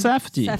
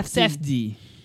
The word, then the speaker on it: Safdie: Como é que é a pronúncia do nome? Safdie. Safdie. – Pulei o F, é, pulei o I.